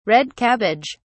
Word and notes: red cabbage: British English

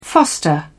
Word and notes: foster: British English

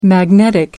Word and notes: magnetic: American English

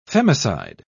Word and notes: femicide: British English

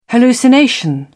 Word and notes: hallucination: British English

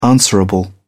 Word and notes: answerable: British English

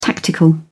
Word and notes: tactical: British English

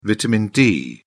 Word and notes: vitamin d: British English

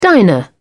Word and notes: diner: British English

